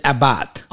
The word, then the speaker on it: Abarth: Is it Abarth? – Abarth.